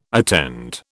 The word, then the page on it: attend: British English